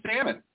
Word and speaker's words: salmon: It sounds to me, it sounds fancier than salmon. You're like, salmon? It's got like a whole nother L in there. So what do you, is there a silent L in salmon? – I guess. It's spelled S-A-L-M-O-N, right?